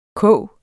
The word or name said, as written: kog